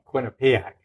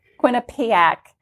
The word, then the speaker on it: Quinnipiac: Quinnipiac. Did I say that right? – Quinnipiac. – Quinnipiac.